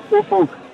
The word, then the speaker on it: Ufuk: It's Ufuk, but we all call him Ufi to sort of save the embarrassment. – Ufie. Has anyone ever said the magic word? Because it's spelt U-F-U-K.